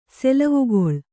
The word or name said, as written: ಸೆಲವುಗೊಳ್